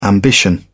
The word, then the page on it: ambition: British English